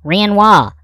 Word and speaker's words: Renoir: Anyhow, her last name is R-E-N-O-I-R. And that Frenchy feller said you're supposed to pronounce it Renoir. And I said, well, why don't you spell it R-E-N-W-A-H, Renoir? And he said, I don't know, but that's how you say it.